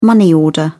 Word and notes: money order: British English

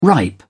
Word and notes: ripe: British English

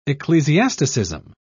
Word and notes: ecclesiasticism: British English

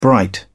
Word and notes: bright: British English

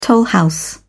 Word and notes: tollhouse: British English

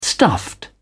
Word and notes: stuffed: British English

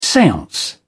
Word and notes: seance: British English

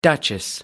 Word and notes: duchess: American English

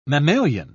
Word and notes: mammalian: British English

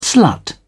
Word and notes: slut: British English

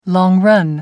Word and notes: long-run: American English

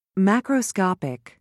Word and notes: macroscopic: British English

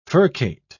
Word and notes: furcate: British English

